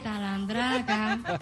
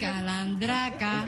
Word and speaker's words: calandraca: No se dice talandraca, ¿calandraca? Es calandraca.